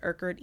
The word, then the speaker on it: Erkerd: C-H-E-N-A-E. And my last name is Erkerd, E-R-K-E-R-D.